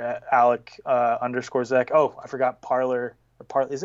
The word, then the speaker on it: Parler: Parler. – Yeah. Oh, dude, well, yeah, but it's pronounced Parlay. Because that means Parler,